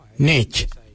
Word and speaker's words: Ngeidj: Now let's take that out, D-J-I-L, and let's put another word, which is Y-A-N-G, Nganyang. Nganyang means give me. For I, we say Nech. Nech. It's spelled N-G-E-I-D-J.